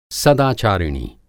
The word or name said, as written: सदाचारिणी